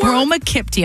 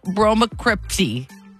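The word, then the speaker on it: bromocriptine: Is the P silent? – Bromokipti. – Pitti. Oh, so no, it's not silent. – Bromokipti. – All right, yeah. – Yeah, phonetically, that's how it's pronounced.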